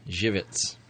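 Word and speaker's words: Żywiec: This is called Zivitz. – Zivitz. And it's spelled exactly like it's pronounced. Z-Y-W-I-E-C.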